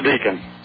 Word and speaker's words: Deken: This is Deacon. – Deacon. That's a cool name. Is that spelled like it sounds? D-E-A-C-O-N? – D-E-K-E-N. – D-E-K-E-N. So yep. It looks like Deacon, but is that a foreign name? – No, it's actually from a Steely Dan song.